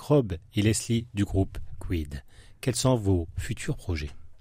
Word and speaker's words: CUYD: Alors, je vous propose maintenant d'écouter le titre Réveille-toi du dernier album Les Lumières sombres, donc de Quid, C-U-Y-D, ça s'écrit comme ça.